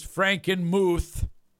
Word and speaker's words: Frankenmuth: The correct pronunciation is Frankenmuth.